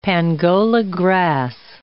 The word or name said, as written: pangola grass